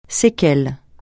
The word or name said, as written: séquelle